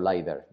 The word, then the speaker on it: LIDAR: Pues que tiene el mismo diseño que los Pro, pero no tiene la pantalla de 120 Hz y el escáner LIDAR o LIDAR, ¿vale? Yo he escuchado las dos pronunciaciones, quedaos con la que más os guste.